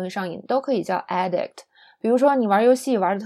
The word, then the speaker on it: addict: 好，那这边还有另外一个知识点，就是这个名词形式不光是 addiction 一个上瘾，还有一个这个 addict 长相不变，但是呢这个发音变了， addict 现在重音移到了前边，这个叫瘾君子，指的是就是不管干什么上瘾的人是吧。